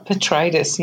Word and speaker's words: Petraitis: You are Petraitis, yeah.